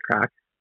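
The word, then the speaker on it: craic: Like it's spelled C-R-A-I-C. C-R-A-I-C. It was crack.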